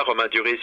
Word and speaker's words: Romain Duris: Is that how you pronounce his last name? – Yeah, Romain Duris, yeah.